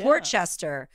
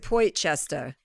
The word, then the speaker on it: Port Chester: Port Chester. Somebody said I have to pronounce it Poitchester. – No. – I was like, I don't think I'm going to do that.